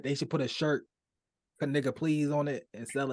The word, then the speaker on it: Knigga: It's pronounced Kaniga. K-N-I-G-G-A.